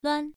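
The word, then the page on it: luan: tone 1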